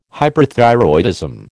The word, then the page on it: hyperthyroidism: American English